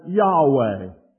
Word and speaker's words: Yahweh: And so therefore, later on, a pronunciation was given to this wonderful title, and it came about to be said as Yahweh.